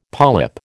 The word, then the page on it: polyp: American English